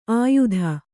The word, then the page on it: ಅಯುತ: kannada